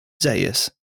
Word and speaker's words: Zaius: Thank you so, so much, Dr. Zeus. Yeah. Zaius. Z-A-I-U-S. Zaius? Zaius.